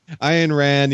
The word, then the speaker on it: Ayn Rand: Yes, Ayn Rand,